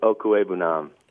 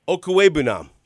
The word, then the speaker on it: Okwuegbunam: But I would like you to tell me how you pronounce Albert O's last name, please. – Albert Okwuegbunam. – Okwuegbunam. – Yep.